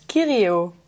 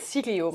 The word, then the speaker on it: Kirio: Kirio. Kirio. – Iedereen zegt altijd Sirio, maar het is Kirio.